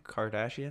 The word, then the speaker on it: Kardashian: Kardashian? – Yeah, yeah.